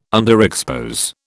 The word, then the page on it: underexpose: British English